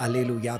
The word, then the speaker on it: Alleluja: Und Halleluja, das war die englische Aussprache, Halleluja, die deutsche, und Alleluja, die italienische. Da sieht es jetzt auch nicht so schrecklich unterschiedlich.